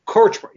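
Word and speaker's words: Kortchmar: That is not true from what I remember, because didn't they start making a second album with Cherone that Danny Karchmore how do you say his name? – Korchmar.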